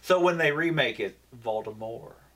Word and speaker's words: Voldemort: It's Voldemort. Yeah. – The T is silent.